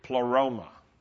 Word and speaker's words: pleroma: And in Greek, pleroma. I happen to like the Greek, pleroma. Can you say that? It rhymes with aroma.